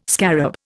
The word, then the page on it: scarab: Indian